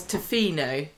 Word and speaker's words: Tofino: it literally, the only word on the whole postcard was Tofino.